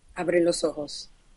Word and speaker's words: Abre los ojos: Abre los ojos.